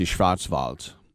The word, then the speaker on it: Schwarzwald: Wald, or W-A-L-D, Wald. Wald means forest. But a W in German is pronounced like a V, and if the D comes at the end of a word, it's pronounced like a T. So, Schwarzwald. Ja?